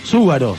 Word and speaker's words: Zúgaro: Cuando empieza acá, quiero algo con el tercer programa y todavía no aclaré que mi apellido es Zúgaro. Z-U. Acento en la U. G-A-R-O, ¿no?